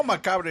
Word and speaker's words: macabre: You know, macabre is just after the Spanish pronunciation, macabre. – It's macabre. Macabre.